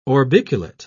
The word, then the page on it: orbiculate: British English